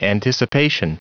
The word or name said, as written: anticipation